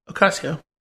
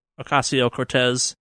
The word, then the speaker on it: Ocasio-Cortez: Ocasio. – Ocasio-Cortez.